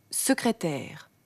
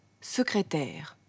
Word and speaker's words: Sekretär: Das S am Wortanfang ist sozusagen ein scharfes S. Man darf es nicht stimmhaft, also gesummt sprechen. Sekretär. Sekretär. Jedes S am Wortanfang müssen Sie stimmlos, ohne Stimmton sprechen.